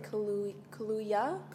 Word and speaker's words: Kaluuya: I would say Kaluuya. – Kaluuya. – Because Kaluuya, to me, I picture that being spelled differently because of Kaluuya the drink. – Mm. – So. – Possibly. – Because that's spelled with an L and this is spelled with a Y. – So Daniel Kaluuya. – Kaluuya. – Yeah. – It don't sound right, but you know what? We'll rock with it.